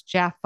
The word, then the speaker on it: Jaffa: And he's like, well, I already thought I was saying it right. And I guess it's Jaffa.